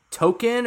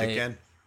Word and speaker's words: Tekken: Is it Token or Tekken? TKN by Tekken.